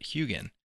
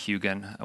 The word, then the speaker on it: Huginn: And I've always pronounced it Huginn. When I've looked online for someone who might actually know what they're talking about, I've heard Huginn. I've never actually heard Huginn, which is what a lot of people say in the open source community.